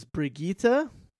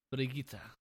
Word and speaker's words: Brigitte: Moira, and now I guess the proper pronunciation is Brigitte. – Brigitte.